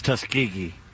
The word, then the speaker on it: Tuskegee: Tuskegee? – It's Tuskegee. Always has been, always will be.